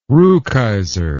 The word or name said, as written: rukeyser